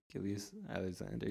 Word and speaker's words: Gilgeous-Alexander: Shai Gilgeous-Alexander. – Gilgeous-Alexander, yeah.